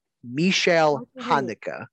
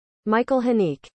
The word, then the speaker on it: Michael Haneke: Even though it's spelled Michael, I'm pretty and even the last name too. Fucking last name. I'm pretty sure that's pronounced Michelle Haneke. – No. – What did they say? – No. – Michael Haneke. Michael Haneke.